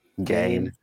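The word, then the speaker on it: Gane: Gain.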